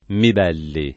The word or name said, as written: Mibelli